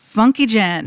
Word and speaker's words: Funkyjenn: How do you say it? I've been trying to figure this out like all week. – Funkyjenn. – Is it Funkyjenn? – Yeah, it's one word.